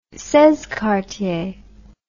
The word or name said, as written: seize quartiers